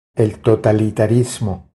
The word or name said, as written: El totalitarismo